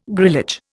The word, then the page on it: grillage: Indian